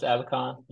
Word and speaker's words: Abacon: You can find me on LinkedIn under Chris Abacon.